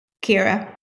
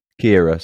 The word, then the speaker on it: Ciara: Is it Ciara Breen or Kiara? – Kira. – Kira, sorry, that's me butchering it.